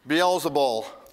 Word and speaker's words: Beelzebul: Derek came to me before the service this morning and said, "Okay, you pronounce this Beelzebul, right?" I said, "Yeah, as far as I know, that's the best one I can come up with. Beelzebul."